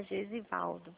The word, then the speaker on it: Gesivaldo: Ô, Gesilvado, como é que fala o seu nome? Me ensina, por favor. – Gesivaldo? – Ah, Gesivaldo. Elisângela, tudo bem? – Tudo bem?